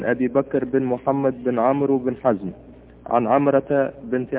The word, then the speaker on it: عمرو: عمر هذه عمر تكتب بالواو تفريقا لها عن عمر بس الواو ما تنطق يعني ما نقول مثلا عن أبي بكر محمد بن عمرو بن حزم نقول بن عمرو